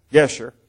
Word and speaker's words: Geshur: So Absalom fled and went to Geshur, I guess is the correct pronunciation. Pardon me? Geshur.